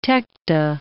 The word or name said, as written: tecta